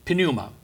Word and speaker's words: pneuma: So I have to clue you in that the word Spirit in the Greek is pneuma. Now, we use that word for pneumonia, pneumatic hammer, and so on. We don't pronounce the P, but in the Greek you do. Pneuma.